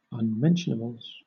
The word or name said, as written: unmentionables